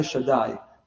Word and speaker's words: Shaddai: This name of God, we pronounce it Shakai Shaddai.